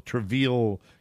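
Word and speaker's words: Travilah: Treville Gateway. – It rhymes with David Seville. – Treville Gateway Drive in Rockville.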